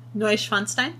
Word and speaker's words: Neuschwanstein: Neuschwanstein.